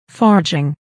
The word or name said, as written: forging